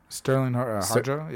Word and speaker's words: Sterling Harjo: There's even, you know, I was recently listening to an interview with Starlin. Is that how you say his name? Starlin Harjo? – Sterling Harjo, yeah.